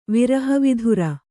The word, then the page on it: ವಿರಹವಿಧುರ: viraha vidhura